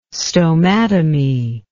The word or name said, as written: stomatomy